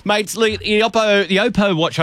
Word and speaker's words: Oppo: The Watch X3 from Oppo, that's how you pronounce it, by the way, yeah. Oppo, I was told by Oppo themselves,